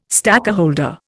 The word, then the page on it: stakeholder: Indian